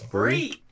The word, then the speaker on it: brie: How do you say that fancy cheese? Is it bray? – All I'm thinking... – It's brie.